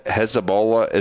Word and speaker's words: Hezbollah: What is, I don't know a whole lot about the Hezbollah.